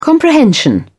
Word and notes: comprehension: British English

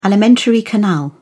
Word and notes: alimentary canal: British English

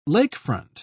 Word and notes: lakefront: British English

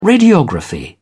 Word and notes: radiography: British English

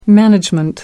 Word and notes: management: American English

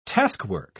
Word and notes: taskwork: British English

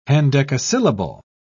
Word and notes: hendecasyllable: British English

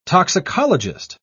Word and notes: toxicologist: British English

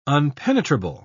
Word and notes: unpenetrable: British English

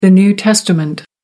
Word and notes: new testament: American English

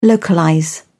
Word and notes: localise: British English

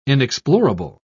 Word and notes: inexplorable: British English

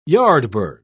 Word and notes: yardbird: British English

